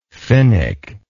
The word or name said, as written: finick